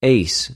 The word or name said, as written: εἰς